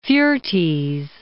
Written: Fuertes

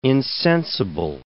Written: insensible

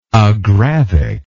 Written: agravic

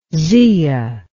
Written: zea

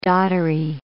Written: doddery

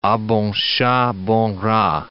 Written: a bon chat, bon rat

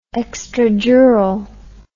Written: extradural

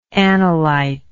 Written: anolyte

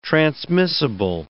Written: transmissible

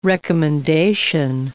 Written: recommendation

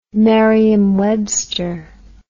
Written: merriam webster

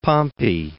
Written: Pompey

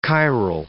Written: chiral

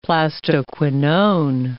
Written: plastoquinone

